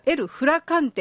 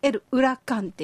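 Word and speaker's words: Huracán: L、ウラカンって言います。スペイン語は H を読まないので、スペルだけ見てると L フラカンっていう感じでローマ字読みだと読みたくなるんですけども、 L ウラカンっていうんですね。 H 発音しません。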